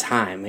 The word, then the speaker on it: Haim: It's Haim.